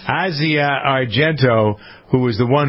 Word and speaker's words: Asia: I say Asia. – It looks like Asia, but it's Asia.